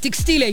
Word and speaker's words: текстиля: И с ударением на «и», и с акцентом на «я», но именно ударный слог с буквой «и» является верным. Поэтому правильно говорить «текстиля», Юрий. «Текстиля».